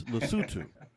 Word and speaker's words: Lesotho: it's Lesotho, and I said it wrong for years, and I'm sorry about that to the country of Lesotho.